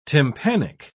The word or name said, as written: tympanic